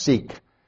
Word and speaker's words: Siq: So the gorge is called S-I-Q. It's like, I guess it's pronounced Sikh.